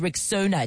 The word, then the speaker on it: Rexona: It's pronounced Rexona.